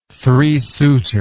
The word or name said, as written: three-suiter